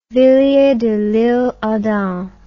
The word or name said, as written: villiers de lisle adam